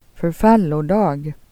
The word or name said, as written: dag